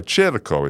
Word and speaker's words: Circo: And then I'm also doing Circo Circo is how you say it, Italian Kitchen, which is in the Skyway Marina District, and that's Sundays 11 a.m.